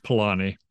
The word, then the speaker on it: Polanyi: how did you say his name? Polanyi. – Yeah. It's more straightforward than it's out, or than it looks. Yeah. It's just Polanyi.